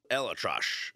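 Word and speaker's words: ElAttrache: The interesting part of it is the doctor that performed the surgery, Dodgers head physician, Dr. Neil Elatrosh. Is that how you pronounce it, McKenzie? – Exactly. Well done.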